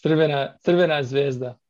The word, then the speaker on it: Crvena Zvezda: And then would you be so kind as to give us the proper name for Red Star Belgrade? Because I'm going to just butcher that. – It's Crvena Zvezda Belgrade.